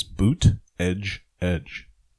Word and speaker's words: Buttigieg: The best way to pronounce his name yeah. is Boot Edge Edge. Buttigieg. That's what somebody told Buttigieg.